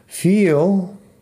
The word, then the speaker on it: feel: La única diferencia en pronunciación es que tiene la I larga. Feel. La diferencia entre feel y fill es que feel tiene la I larga y fill de llenar tiene la I corta.